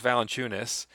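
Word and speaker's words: Valančiūnas: they started Jonas Valanciunas.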